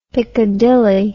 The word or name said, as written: piccadilly